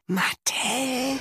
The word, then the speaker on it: Mattel: Mattel.